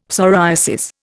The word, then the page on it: psoriasis: Indian